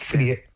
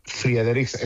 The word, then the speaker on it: Frederiksen: Es Frederiksen. – Frederiksen.